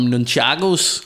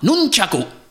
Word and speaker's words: nunchaku: om nunchakos. Og jeg siger jo, som den danske er sådan, nunchako. Og han siger, ah, nunchaku. Nunchaku. Så i Japan udtaler man det, nunchaku.